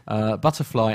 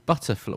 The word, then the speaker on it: BTRFLY: That could be about to change with a new app called Butterfly. Well, I'm pronouncing it Butterfly, but it's spelt BTRFLY, that offers air travellers the opportunity to connect in real life with like-minded people.